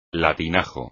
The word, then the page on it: latinajo: espagnol